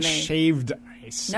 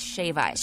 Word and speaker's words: shave ice: It's not shaved ice. – No, it isn't. – Now we're talking about pronunciation on this podcast. – Yeah. So let's be correct. Let's be articulate. It's shave ice.